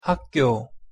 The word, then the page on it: 학교: Korean